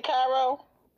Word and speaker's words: Cairo: Oh my God, you don't know how I say Cairo? – Cairo, Egypt, in Hong Kong. Because I said it wrong last time.